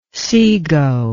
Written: sego

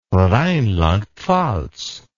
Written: rheinland pfalz

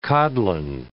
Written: codlin

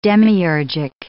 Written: demiurgic

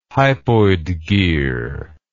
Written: hypoid gear

